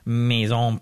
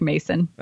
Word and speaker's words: Maison: So the next question coming in from your BFF, Maison Pierrefort. Wait, am I saying that right? – Well, you said it very French, but she just says it like Mason.